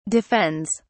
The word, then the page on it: defends: British English